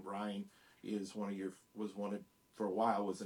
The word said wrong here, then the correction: Ayn Rand: So Ayn Rand,